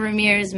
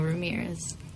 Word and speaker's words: Ramirez: Hano Ramirez McNamee.